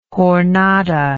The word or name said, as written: jornada